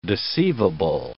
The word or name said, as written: deceivable